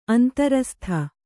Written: ಅಂತರಸ್ಥ

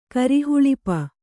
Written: ಕರಿಹುಳಿಪ

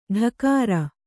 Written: ಢಕಾರ

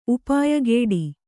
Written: ಉಪಾಯಗೆಡು

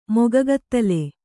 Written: ಮೊಗಗತ್ತಲೆ

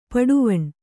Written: ಪಟ್ಟವಣೆ

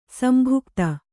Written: ಸಂಭುಕ್ತ

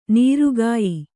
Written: ನೊರೆಕಾಯಿ

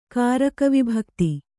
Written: ಕಾರಕವಿಭಕ್ತಿ

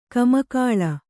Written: ಕಮ್ಮಗೋಲ್

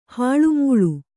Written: ಹಾಳುಮೂಳ

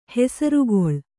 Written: ಹೆಸರ್ಗೊಳ್

